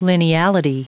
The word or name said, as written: lineality